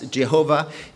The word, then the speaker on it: Jehovah: His name, you know what it is, Yud-Heh-Vav-Heh, we pronounce today as Jehovah, is mentioned 23 times.